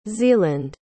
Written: zealand

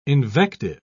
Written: invective

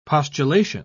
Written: postulation

